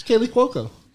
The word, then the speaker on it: Kaley Cuoco: No, I said it right. It's Kaley Cuoco.